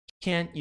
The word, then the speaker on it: can't: Perceba que o T, a pronúncia do T, enfatizando isso que é importante, a pronúncia do T, ela é mais a intenção do que a pronúncia em si. Você não pronuncia o T. Fala can't. Perceba que eu tô fazendo o T na minha boca, mas eu não pronuncio. Can't. Eu não pronuncio isso.